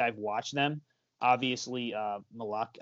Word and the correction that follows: Malachi: I think you pronounce his name Malachi Flynn. – I think it's Malachi Flynn. – Malachi Flynn. Not Malak. Oh, yeah, that would make sense.